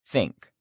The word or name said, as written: think